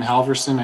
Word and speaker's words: Halverson: Chairman Regeer, members of the committee, my name is Benjamin Halverson. I spell that H-A-L-V-E-R-S-O-N.